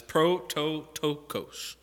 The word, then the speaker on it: prototokos: I didn't mispronounce that. It's prototokos.